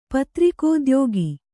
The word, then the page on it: ಪತ್ರಿಕೋದ್ಯೋಗ: kannada